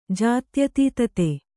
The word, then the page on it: ಜಾತ್ಯತೀತತೆ: jātyatītate